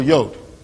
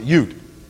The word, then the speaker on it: Yud: The letter on the far left is Yud or Yod, whichever how you pronounce it, Yud.